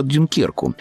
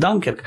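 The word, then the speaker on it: Dunkirk: Новый фильм Кристофера Нолана, который называется по-русски «Дюнкерк», а по-английски, наверное, правильно говорить «Данкерк», это одна из оптимистических историй, если это слово только уместно, Второй мировой войны.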